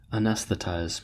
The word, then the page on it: anesthetize: American English